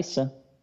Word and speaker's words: Isa: You know, Mount Isa, or Mount, you said it right, Isa, right? – Yeah.